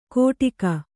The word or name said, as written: ಕೋಟಿಕ